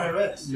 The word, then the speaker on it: Haaretz: Haaretz. – Haaretz. – How's your friend? – Haaretz. Oh, yeah. It's nicer more, Aretz.